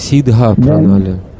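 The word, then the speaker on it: сиддха-пранали: Я читал в Чайтанья Шикшамрите, говорится о Сита Пранале. И я уже задавал вам этот вопрос. Это необходимо, чтобы... Прежде всего, нужно говорить Сидха Пранале.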